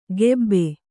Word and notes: ಕೆಬ್ಬೆ: kannada

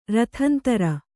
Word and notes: ರಥಂತರ: kannada

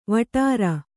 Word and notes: ವಟ್ಟಾರ: kannada

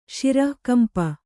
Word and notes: ಶಿರಃಕಂಪ: śirahkampa

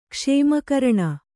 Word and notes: ಕ್ಷೇಮಕರಣ: kannada

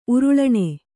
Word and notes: ಉರುಳಾಣಿ: uruḷāṇi

